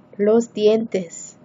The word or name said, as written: los dientes